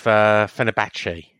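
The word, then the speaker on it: Fenerbahçe: But this time he's linked with Fenerbahce. Is that how you pronounce it? – Yes. Well done.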